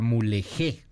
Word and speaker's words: Mulegé: Mulegé, eh, así, así se pronuncia, perdóneme, San Ignacio y Vizcaíno.